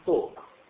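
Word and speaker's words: talk: يعني حتى مثلا بعض العلماء بيقولوا مثلا توك، كلمة توك باللغة الإنجليزية لاحظ الحرف اللام ما بن لا يلفظ.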